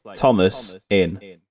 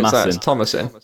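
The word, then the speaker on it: Thomasin: No, it's like Thomas in. – Oh, there you go, Tomasin.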